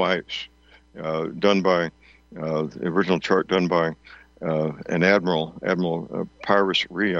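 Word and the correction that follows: Piri Reis: The way they pronounce it today is Perry Reese.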